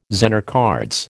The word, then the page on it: Zener cards: American English